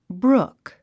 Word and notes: brook: brúk; ブルク

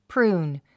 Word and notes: prune: prúːn; プルーン

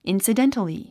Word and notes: incidentally: ìnsədéntəli; インスィデンタリィ

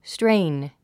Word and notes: strain: stréin; ストレイン

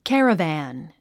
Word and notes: caravan: kǽrəvæ`n; キャラバン

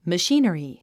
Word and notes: machinery: məʃíːn ə ri; マシィナリィ

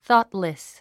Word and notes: thoughtless: θɔ́ːtlis; ソートレス

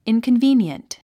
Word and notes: inconvenient: ìnkənvíːniənt; インコンビィニエント

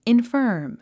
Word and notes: infirm: infə’ː r m; インファーム

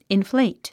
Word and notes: inflate: infléit; インフレェイト

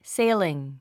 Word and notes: sailing: séiliŋ; セェィリン

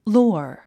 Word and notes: lore: lɔ́ː r; ロォア

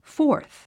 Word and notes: fourth: fɔ’ː r θ; フォース

